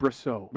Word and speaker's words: Brosseau: And the dude who broke the no-hitter up last night, I might be saying his name wrong, but Mike Brosu, he's playing second base. – I think it's Mike Brosseau.